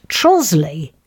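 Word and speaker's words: Trottiscliffe: My favourite, though, not rude, is Trottiscliff, pronounced Trosley, with nearby Rotham, W-R-O-T-H, pronounced Rootum, and Igatham, pronounced Item.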